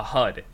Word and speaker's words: HUD: And how would you pronounce that as an acronym? A HUD, exactly. I'm listening to it in audiobook format, and every single time, in the first book, they pronounce it right. They say HUD when they're talking about it.